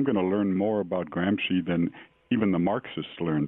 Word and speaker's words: Gramsci: Gramsci.